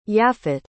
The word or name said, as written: yaphet